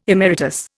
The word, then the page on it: emeritus: Indian